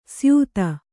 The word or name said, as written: ಸ್ಯೂತಿ